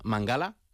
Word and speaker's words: Mangala: Él ha comentado que se pronuncia Mangala.